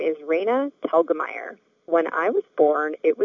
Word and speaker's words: Telgemeier: It's Telgemeier, which rhymes with Helga, like the name Helga, and then Meyer, as in Oscar Meyer Wiener.